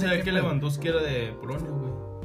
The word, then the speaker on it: Lewandowski: ¿Cómo se pronuncia, güey? – Sí, güey, Lewandowski.